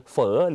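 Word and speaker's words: phở: คือเฝอหรือว่าภาษาเวียดนามออกเสียงว่าเฝอออกเสียงพร้อมกันเฝอกาเฝอกาเฝอบ่อเฝอบ่อเฝอกากาคือไก่บ่อคือวัวเฝอกาคือเฝอไก่ส่วนเฝอบ่อคือเฝอวัวนั่นเองต่อมาเฝอก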